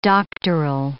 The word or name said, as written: doctoral